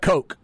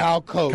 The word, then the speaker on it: Koch: The wide receiver, is it Kyle Cooch? – It's Coke. Yeah, it's spelled K-O-C-H, but he pronounces it Coke. – Okay, yeah. – Kyle Coke.